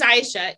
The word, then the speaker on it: Aisha: And people, so why I pronounce my name Aisha, it is Aisha, is because not one of my teachers, I would know when they'd get to my name, because they would stumble. And I just wanted them to say whatever. So somebody started calling me Aisha, they could pronounce it.